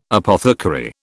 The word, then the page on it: apothecary: British English